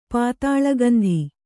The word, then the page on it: ಪಾತಾಳಗಂಧಿ: pātāḷa gandhi